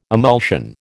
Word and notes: emulsion: American English

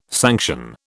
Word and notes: sanction: British English

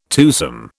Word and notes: twosome: British English

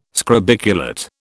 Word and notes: scrobiculate: British English

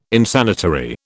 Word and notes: Insanitary: British English

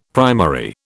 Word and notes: primary: British English

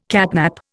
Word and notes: catnap: Indian

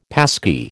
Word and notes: passkey: American English

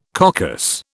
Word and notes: coccus: British English

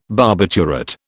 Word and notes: barbiturate: British English

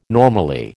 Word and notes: normally: American English

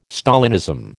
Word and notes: Stalinism: American English

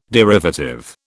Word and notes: derivative: British English

